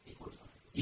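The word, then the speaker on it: oikotimes: Because he likes the Greek pronunciation. – Yeah, his Greek pronunciation. – It's ikotimes. – Ikotimes.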